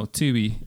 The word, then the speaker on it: Tubi: No, Tubi.